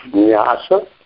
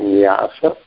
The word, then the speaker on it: nyasa: The word that's used is nyasa, you know, with the long A, nyasa.